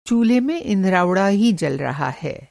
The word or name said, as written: इंधराउड़ा